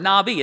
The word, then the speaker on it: Navi: It's sort of an N-A-V-I, but the V is sort of pronounced as a B, or the B, it can be pronounced as a V, Navi. Am I right? It's the B pronounced like the V if it doesn't have the dot in it.